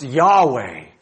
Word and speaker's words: Yahweh: It's actually, in Hebrew, supposed to be pronounced Yahweh. That's His name.